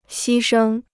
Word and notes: 牺牲: xī shēng

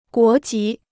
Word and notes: 国籍: guó jí

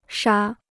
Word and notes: 杀: shā

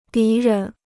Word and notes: 敌人: dí rén

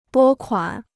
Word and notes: 拨款: bō kuǎn

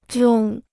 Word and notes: 中: zhòng

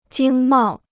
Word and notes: 经贸: jīng mào

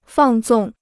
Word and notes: 放纵: fàng zòng

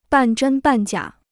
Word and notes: 半真半假: bàn zhēn bàn jiǎ

